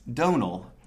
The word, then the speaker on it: Donal: It's Donal, like tonal.